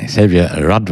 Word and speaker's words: Xavier Rudd: Der australische Sänger, Songwriter, Multiinstrumentalist Xavier Rudd war das, richtig ausgesprochen.